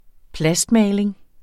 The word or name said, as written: plastmaling